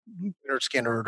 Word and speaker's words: Lynyrd Skynyrd: And so for me, I guess the best album of all time is probably Lynyrd Skynyrd's, pronounced Lynyrd Skynyrd is actually the title of it.